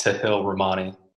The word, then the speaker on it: Tahilramani: I'm obviously used to it, but it's pronounced Tahilramani.